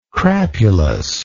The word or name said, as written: crapulousness